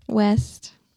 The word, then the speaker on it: Wuest: Well, how do you say it? – West. – West? – Yeah. – How's it spelled? – W-U-E-S-T. She skips the U.